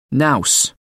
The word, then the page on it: nous: American English